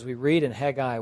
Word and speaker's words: Haggai: So A-I, the ending diphthong there, the two vowels that make one sound, is like in the word aisle, A-I-S-L-E, like the center aisle of the church, I. So Haggai is the way to say that, just like the city that's spelled A-I in Joshua.